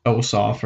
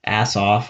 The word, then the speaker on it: Ossoff: And they know that if they can get Warnock and Ossoff or Assoff, however it's pronounced, someone, as I said before, someone said it's pronounced off. If, that is, they understand that if those two win, they can do a lot to this country.